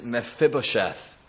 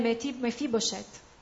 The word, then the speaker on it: Mephibosheth: His name is Mephibosheth. – E il suo nome è Mephibosheth.